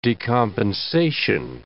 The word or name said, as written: decompensation